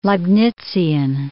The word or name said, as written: Leibnizian